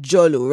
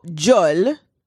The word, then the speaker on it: djol: So jol, pronounced, I guess, with a J, but it's really D-J-O-L in Creole, but jol means mouth.